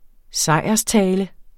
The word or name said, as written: sejrstale